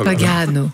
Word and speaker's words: Pagano: L'accent est sur le deuxième A. Oh là là. – Pagano.